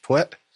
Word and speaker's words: Puett: P-U-E-T-T. There's five letters. Okay. But yeah, it was originally pronounced Pwit, and it was spelled with a Y.